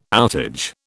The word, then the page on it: outage: British English